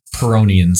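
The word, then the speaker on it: Pyrrhonian: So speaking in that vein, let's head on a little bit and talk about what separates Cartesian skepticism from Pironian skepticism. Did I say that right? – You did.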